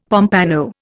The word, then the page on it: pompano: Indian